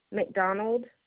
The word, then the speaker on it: McDonald: Lindsay, L-I-N-D-S-A-Y, McDonald, M-C-D-O-N-A-L-D.